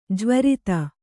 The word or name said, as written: ಜ್ವರಿತ